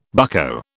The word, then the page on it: bucko: British English